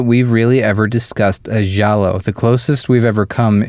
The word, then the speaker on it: giallo: So I guess the intent is this is supposed to be, is it a giallo film? Is that how you say it? – Giallo. – Giallo? – Yeah, well, my voice kind of cracked. Giallo.